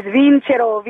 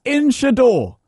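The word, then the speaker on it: vincerò: And the last phrase, which you pronounced incorrectly, is vincero, vincero. It means I will win, I will Nonsense. – Win. – It's Inchador.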